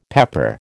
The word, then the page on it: pepper: American English